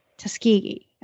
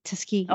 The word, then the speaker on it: Tuskegee: How did you pronounce again the name of the university? – Tuskegee University. – Tuskegee. Okay, I've said it the total like Yankee way. Tuskegee.